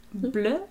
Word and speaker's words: Bleu: Le Cordon Bleu? – Bleu. I think that's the French word.